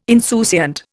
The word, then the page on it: insouciant: Indian